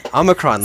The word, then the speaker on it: Omicron: Omicron? – Omicron. Omicron. Omicron. No. Omicron. Like you say it like it's all one word.